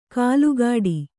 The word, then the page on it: ಕಾಲುಗಾಡಿ: kālugāḍi